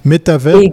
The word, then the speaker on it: métavers: Métavers pour contraction de méta-univers, pour ça qu'on ne prononce pas le S à la fin.